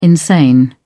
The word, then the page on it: insane: British English